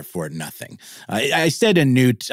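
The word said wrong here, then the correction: nouht: I kind of knew it was pronounced naught, but it's spelled differently. It's spelled N-O-U-H-T.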